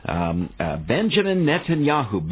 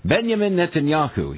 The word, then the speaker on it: Benjamin Netanyahu: Benjamin Netanyahu. Benjamin Netanyahu. I have to be careful not to allow my American English accent to override a correct pronunciation of the Hebrew name, right? Benjamin Netanyahu.